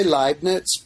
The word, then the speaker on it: Leibniz: Well, we do say Leibniz, but that's okay. It's an Leibniz.